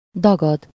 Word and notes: gonfler: France; [ɡɔ̃.fle]

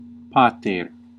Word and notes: parent: [ˈpɛɹ.ənt]; /ˈpɛəɹənt/; /ˈpæɹənt/; /ˈpeəɹənt/

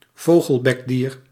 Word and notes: vogelbekdier: /ˈʋoːɣəlˌbɛkˌdiːr/; /vo.xəl.bɛk.dir/

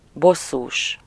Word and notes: annoyed: /ə.ˈnɔɪd/

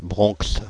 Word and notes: Bronx: /bʁɔ̃ks/; France (Île-de-France)

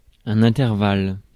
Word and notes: intervalle: [ɛ̃.tɛʁ.val]

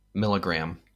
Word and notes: milligram: American English; IPA: /ˈmɪlɪɡɹæm/

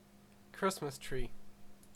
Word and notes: Baum: [baʊ̯m]

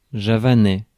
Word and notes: javanais: France; [ʒa.va.nɛ]

